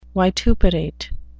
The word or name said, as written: vituperate